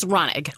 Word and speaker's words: Raunig: It's Raunig.